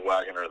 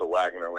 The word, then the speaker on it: Waggener: It's just pronounced Waggener, though. But okay.